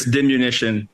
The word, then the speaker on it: diminution: Yeah, Maryland, it's diminution is, I think, the proper pronunciation.